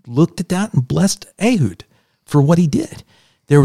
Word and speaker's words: Ehud: Ehud in scripture, or as Americans pronounce it, Ehud, right?